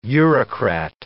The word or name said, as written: Eurocrat